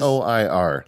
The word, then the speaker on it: Noir: The one that I use is called Noir. No, is that how you say it? – Noir. I would say noir, like film noir.